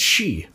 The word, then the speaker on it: sidhe: The word is S-I-D-H-E. Oh, yeah, that's she. What? Come on, guys. Get your language together. – Another fun word, Mahail, M-A-E-L-I, is the name of her son, and he's missing.